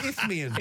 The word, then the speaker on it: Isthmian: Think of an if and a meanian. Isthmian.